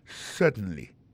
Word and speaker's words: certainly: Alors, si besoin d'appuyer, mettez plus j'ai dans yes là, on dit yes, certainly. Alors, en parlant de accent, gardez bien pour un mot certainly, accent tombé sur le premier syllabe là.